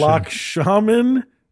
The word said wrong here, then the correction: Lakshmanan: is his first name. – There's one more syllable. – Lakshmanan. Sorry. – Lakshmanan.